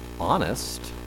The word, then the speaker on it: honest: So we now say honest, we've dropped the H, when in the past it was honest with the H.